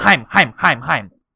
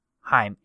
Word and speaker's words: Haim: Do you know who Haim is? The girl band. – Haim. Yeah. Not the underwear. – Haim. I always say it wrong. Haim. H-A-I-M.